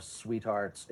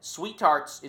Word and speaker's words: SweeTarts: and an unholy amount of SweeTarts and Spree. – I love that you've committed to pronouncing SweeTarts without the second T.